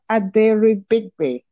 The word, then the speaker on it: Aderibigbe: It's five syllables of consonant clusters. So sometimes I just simply go by Patricia, but I'll just do it, okay? It's Patricia Aderibigbe, okay?